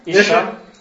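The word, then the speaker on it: Isha (אשה): Yeah, but the accent's on the first syllable. – Isha, Isha.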